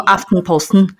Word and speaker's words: Aftenposten: 叫 Aftenposten，而且它是真的，我说最权就是最具权威报纸，不是我自己讲的，因为就它上版了大概十分钟之内。